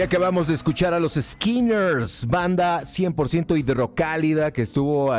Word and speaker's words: SKNNRS: Échenles una oreja, se escriben S-K-N-N-R-S, Skinners, una banda liderada por Juan y las chicas.